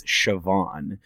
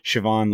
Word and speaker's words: Siobhan: And so it's spelled S-I-O-B-H-A-N. And it's an Irish name. So it's Siobhan, which like the name Siobhan makes sense.